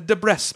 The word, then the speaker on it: Debris: Is that how you pronounce it? – Yeah, that's how you pronounce it. It's a Debris before. Elephant Men, number 41.